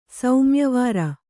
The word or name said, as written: ಸೌಮ್ಯವಾರ